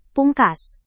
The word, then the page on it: pungkas: Indonesian